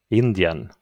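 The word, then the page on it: Indien: Swedish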